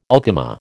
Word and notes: ultima: American English

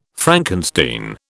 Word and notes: Frankenstein: British English

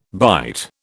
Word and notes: byte: British English

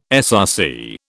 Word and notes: SRC: British English

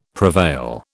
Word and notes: prevail: British English

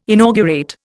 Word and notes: inaugurate: Indian

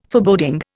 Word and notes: Foreboding: Indian

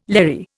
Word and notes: Larry: Indian

